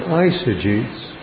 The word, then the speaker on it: eisegetes: They are called eisegetes. Spell it. E-I-S-E-G-T. Yes. I-E-I-S. E-G-E-T-E-S. Eisegetes. E-I-S.